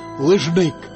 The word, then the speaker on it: лыжмык: Он обнаружил существование ковра под названием «лыжмык» с ударением на последнем слоге.